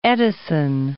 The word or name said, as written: Edison